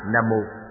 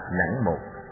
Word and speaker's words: Nam Mô: Chữ Nam Mô tiếng Phạn là Nam Mô, cũng đọc là Nẵng Một, dịch nghĩa là cung kính hướng về.